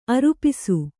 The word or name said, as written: ಆರೋಪಿಸು